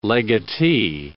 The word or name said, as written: legatee